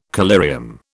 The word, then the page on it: collyrium: British English